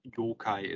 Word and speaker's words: yokai: And in general, if you want to be talk about monsters in general, yokai is the way of pronouncing saying that.